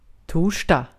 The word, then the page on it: torsdag: /ˈtuːrsdɑːɡ/; [ˈtʰuːʂd̥ɑːɡ]; /ˈtuːrsda/; [ˈtʰuːʂd̥a]